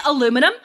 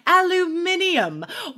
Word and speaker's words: aluminum: aluminum i say aluminum but a british person would say aluminium which i love and sometimes love i'll it.